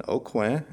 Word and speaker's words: Aucoin: If we remember at the beginning when I said my last name is Kevin Aucoin, that's spelled A-U-C-O-I-N, very French.